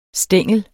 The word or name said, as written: stængel